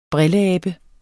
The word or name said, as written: brilleabe